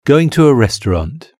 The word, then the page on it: going to a restaurant: British English